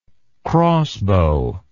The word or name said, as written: crossbow